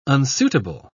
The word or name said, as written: unsuitable